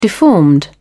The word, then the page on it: deformed: British English